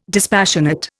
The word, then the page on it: dispassionate: Indian